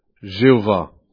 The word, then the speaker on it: Jéhovah: Ça donne un mot comme Yahovah, ou historiquement on a dit Jéhovah, surtout en français. Donc la prononciation en fait de Jéhovah vient de cela. C'est les consonnes de ce mot traduit éternel avec les voyelles du mot pour Seigneur Adonai.